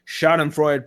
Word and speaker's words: Schadenfreude: But it's time for to get the Schadenfreude and the Schadenfreude Power Hour going. Did I say that right? I think I butchered that, didn't I? – That sounded good to me.